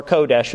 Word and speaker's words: kodesh: You would basically spell that K-A-D-O-S-H, K-A-D-O-S-H, kadosh. Or kodesh would be K-O-D-E-S-H. Kodesh, K-O-D-E-S-H. So that's what the Hebrew term would be for holiness or sanctification, set apartness, that kind of a thing.